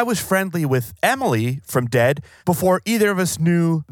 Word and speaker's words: Dehd: The band's name is Dehd. It's spelled D-E-H-D, in case you want to Google it.